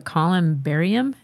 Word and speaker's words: Columbarium: Like, there's at one point in the book, you were talking about the ghost of a little girl at Columbarium.